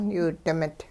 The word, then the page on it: yiwi-dimit: hupa